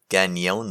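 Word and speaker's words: Cañon: In fact, I went to Walgreens in Ganon City. You have to say it like that because the N with the little squiggly above it. – Oh, it's Ganon then.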